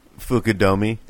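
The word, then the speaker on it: Fukudome: Fukudomi.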